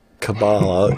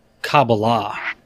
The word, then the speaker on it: Kabbalah: It's not Kabbalah. It's Kabbalah. Right, you emphasize the last syllable unless you're told otherwise emphasize a middle syllable, for example.